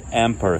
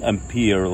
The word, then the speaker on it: Ampere: Okay, Ampere, it's called Ampere here, okay? Not Ampere like we pronounce it in America, it's called Ampere.